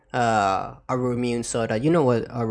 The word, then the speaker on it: Ramune: Ramune. – Yeah, Ramune.